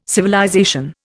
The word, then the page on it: civilization: Indian